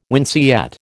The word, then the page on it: winceyette: American English